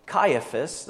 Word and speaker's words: Caiaphas: In verse 49, one of them, Caiaphas,